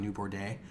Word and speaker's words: New Bordeaux: He is in the rural South of a kind of made-up version of New Orleans called New Bordeaux, whatever the fuck. There's an X at the end of it, and you can't pronounce the X. I know that's what's going on.